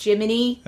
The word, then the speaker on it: Gemini: Your sidekick, like the name is written down Gemini, but it's pronounced Jiminy.